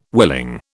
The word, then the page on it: willing: British English